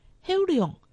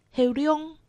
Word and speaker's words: 后龙: 苗栗县后龙镇客家话四线腔发音后龙，海陆腔发音后龙。